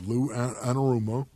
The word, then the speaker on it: Anarumo: So of the three coaches are going to allegedly interview here, Lou Anarumo, right? I said that right. – Mm-hmm.